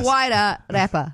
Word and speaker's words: Wairarapa: The Wairarapa.